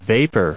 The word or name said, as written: vapor